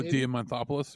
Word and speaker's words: Diamantopoulos: Darkwing has become a recurring character in Disney's DuckTales reboot with, I can't even begin to pronounce this guy's name, Chris Deannopoulos. – Oh, Diamantopoulos or Diamant? Yeah.